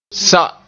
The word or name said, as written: sa